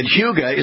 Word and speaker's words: hygge: Yeah, and we want to remind everybody that Hyuga is not spelled the way you're hearing it. The way the Danish spell it is H-Y-G-G-E, so you have to spell it out that way as well, right?